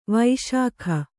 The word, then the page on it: ವಿಶಾಖ: viśakha